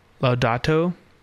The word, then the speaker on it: Laudato: I mean, did he not write Laudatio Si? I'm just wondering. – I don't think that's how you pronounce that. – What is it? – Laudato.